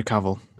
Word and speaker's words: Cavill: It's Henry Cavill. – That's the pronunciation, thank you.